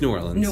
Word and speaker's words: New Orleans: I know you talked about you grew up in Nolens, but it's New Orleans. We don't like Nolens.